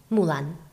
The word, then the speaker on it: Mulan: You have to pronounce it different. – Mulan. This is how you should pronounce it.